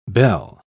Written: bel

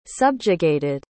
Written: subjugated

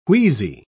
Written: wheezy

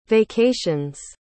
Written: vacations

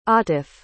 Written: atif

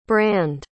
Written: brande

